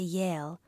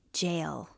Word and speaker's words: Yale: They both went to school in the States, Puppy to Yale, which poor Mommy always mispronounces jail.